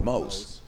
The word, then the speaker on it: Mose: Well, you know, you know what's weird is that you know people people my nickname people call me Mose, so it's M O S E but my oh, name is spelled M O S L E Y.